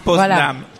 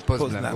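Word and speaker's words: Poznań: Ah, Poznan. – Poznan, voilà, c'est ça.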